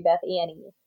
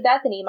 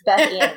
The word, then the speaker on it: Bethany: And for example, my name is Bethany, right? But it's spelled weird. The reason it's spelled weird is because technically it's supposed to be Beth Annie. – Oh. – And if you call me Bethany, my mom will tell you it's Beth Annie.